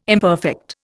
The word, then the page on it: imperfect: Indian